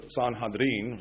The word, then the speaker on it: Sanhedrin: And so, the Jewish Sanhedrin Council, or Sanhedrin, which is the way you pronounce it, was much like the political positions of men today.